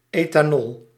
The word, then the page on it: ethanol: /eːtaːˈnɔl/; Dutch